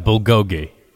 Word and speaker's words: bulgogi: It sounds just as it's spelled, bulgogi.